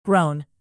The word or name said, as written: thrown